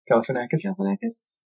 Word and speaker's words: Galifianakis: Galifianakis.